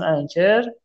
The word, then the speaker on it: Anchor: به اسم انکر تلفظش هم A N C H O R